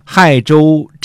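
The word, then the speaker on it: 解州: 最后呢，我弄明白了，这个字儿呢既不读“ 解”，也不读“ 谢”，而是读成“ 亥”。亥州镇的这个地理位置呢，在今天山西运城的亥池西边儿啊。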